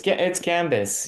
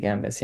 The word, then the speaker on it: Gambis: It's Gambis. Yeah, it's a okay. – French name. We have odd consonants that we pronounce and we don't pronounce, but it's Alexi Gambis.